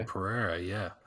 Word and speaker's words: Pereira: Pereira? – Oh, Pereira, yeah.